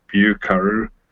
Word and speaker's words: Vieux Carré: Actually, it looks like Vieux Carré, but it's pronounced Vieux Carré.